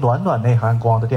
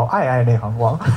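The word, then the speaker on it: 嗳嗳内含光: 有一个叫容易被念成暖暖内涵光的电影，叫爱爱内涵光。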